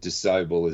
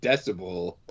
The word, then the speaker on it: decibel: The very next day, the other called it Disciple as well. Like, you've got to be kidding me. What is with your generation that can't pronounce the word decibel? – They're putting the emphasis on the wrong syllable.